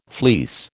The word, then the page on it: fleece: American English